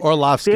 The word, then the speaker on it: Orlovsky: I could say Dan, and I could never say his last name, Orlovsky. Did I say it right? – Orlovsky.